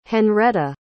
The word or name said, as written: Henretta